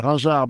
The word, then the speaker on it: Raingeard: Yeah, it's actually Virgile, Raingeard, but it's a pretty tough name for English-speaking person to pronounce, so no blame there, really.